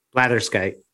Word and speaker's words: blatherskite: Blatherskite? Is that how you say it? – Blatherskite.